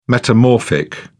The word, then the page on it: metamorphic: British English